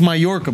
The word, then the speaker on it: Mallorca: He was in Majorca. – He went to... – Mallorca. It's pronounced Mallorca, bro. Yeah, don't say it like that, dude.